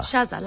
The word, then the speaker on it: Shaza: It's spelled S-H-A-Z-A. – Shaza. – Shaza, like Shazam without the M.